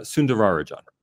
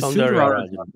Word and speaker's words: Soundararajan: Soundararajan. Okay, so Ms. Soundararajan.